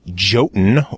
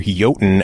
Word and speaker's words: Jotun: Jotun, or Jotun as it's properly pronounced, but they prefer to say Jotun because if you said Jotun, no one would know what to look up for online, and they would probably spell it without a J.